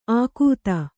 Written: ಆಕೂತ

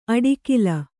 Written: ಅಡಿಕಿಲ್

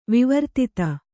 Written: ವಿವರ್ತಿತ

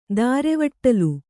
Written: ದಾರೆವಟ್ಟಲ್

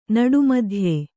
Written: ನಡುಮಧ್ಯೆ

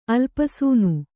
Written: ಅಲ್ಪಸೂನು

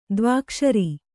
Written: ದ್ವ್ಯಕ್ಷರ

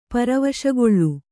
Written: ಪರವಶಗೊಳ್ಳು

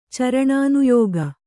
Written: ಚರಣಾನುಯೋಗ

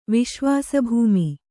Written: ವಿಶ್ವಾಸಭೂಮಿ

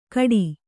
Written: ಘಟಿ

